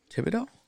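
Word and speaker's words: Thibodeaux: So I have Jacksonville taking, I'm going to butcher his last name, Thibodeau? – Thibodeau. – Is that right? – From Oregon. – Thibodeau.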